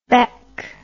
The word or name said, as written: baeck